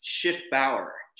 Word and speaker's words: Schiffenbauer: Oh, Schiffenbauer, people do that all the time. It's Schiffenbauer Jr. It's a German name. People get typically the spelling wrong all the time. The C is silent. I have two Fs. It's Bauer, B-A-U-E-R versus Bauer, B-O-W-E-R, which most people think.